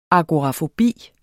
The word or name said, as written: agorafobi